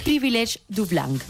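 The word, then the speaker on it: privilège du blanc: a ver si lo digo bien, privilege du blanc. – ¿Ves? Le ha quedado genial.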